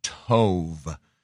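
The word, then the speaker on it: tov (טוב): The word is T-O-W-B in Hebrew, taub. It's actually tov, or, you know, it depends on how you pronounce it. It's T-O-V-E with a long O, like O, so tov.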